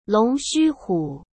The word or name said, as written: Long Xuhu